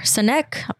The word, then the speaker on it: Sinek: Sinek.